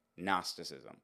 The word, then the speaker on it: Gnosticism: Gnosticism. Now, Gnosticism uh, it starts off with a with a silent G, so it's a very weird word to spell and a very a very weird word to write.